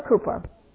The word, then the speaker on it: Cowper: And Brother Cooper, I used to call him Cowper, but I found his name is pronounced Cooper.